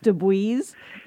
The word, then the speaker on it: Dubuys: And his name is spelled D-U-B-U-Y-S. So I was calling him Dubois, and he said, it's Dubois. And I said, okay, like, close the door so Dubois doesn't come in.